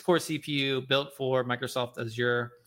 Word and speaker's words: Azure: Azure. – Right? Azure.